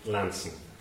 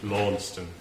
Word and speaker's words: Launceston: So I called it Causley's Haunted Launceston, because I didn't come from Cornwall, and I didn't know that his town is pronounced Lanston. So I thought I had stumbled on this lovely haw sound, Haunted Launceston. But someone quickly corrected me on that.